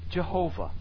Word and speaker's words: Jehovah: There are consonants that are dropped in the name of God, so the pronunciation that we have brought from this would be Jehovah. Jehovah. The name of God. That name that we know as Jehovah, which is really a mispronunciation because it was so holy that they would never say it.